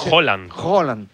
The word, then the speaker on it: Haaland: Es Haaland. – Como si dijeras un queso, ¿no? – ¿Sabes qué pasa el martes?